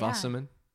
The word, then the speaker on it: Wasserman: Did I pronounce that correctly? – You did. – Or yeah. Wasserman. – Wasserman. – Wasserman. Yep, yep.